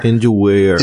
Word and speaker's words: Weir: Andy Weir. – Eu, eu leio, eu leio Weir.